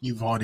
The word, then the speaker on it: Uvalde: Uvalde, yep.